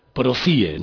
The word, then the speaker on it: PROCIE: PROCIE, ¿no? Lo he dicho bien, ¿no? – Sí, está bien.